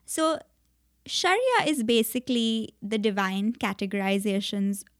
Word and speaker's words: Sharia: Yeah, Sharia.